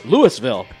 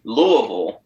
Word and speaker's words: Louisville: Ooh, he attended the University of Kentucky, which possibly explains why he fully pronounces both S's when he says Des Moines, but can't seem to find the single S when he pronounces Louisville.